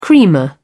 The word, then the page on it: creamer: British English